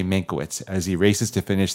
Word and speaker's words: Mankiewicz: It is about Herman Mankiewicz. I believe I'm saying that right. – Mankiewicz. – Anyway. – He's Jewish. Mankiewicz.